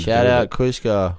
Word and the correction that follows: Kusha: Yeah, Kusha. I cannot believe this guy is on your label and you cannot pronounce his name right. It's Kusha. You always call him Kushka.